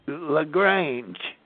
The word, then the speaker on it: LaGrange: LaGrange.